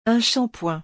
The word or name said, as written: un shampoing